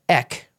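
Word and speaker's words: ek (ἐκ): And that word from is interesting in the Greek, ek, really easy to pronounce, E-K.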